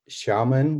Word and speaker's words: Xiamen: It's Xiamen, which is spelled X-I-A-M-E-N.